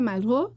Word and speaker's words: Malraux: André Malraux, se escribe Malraux, pero se pronuncia Malraux.